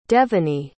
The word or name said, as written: Devany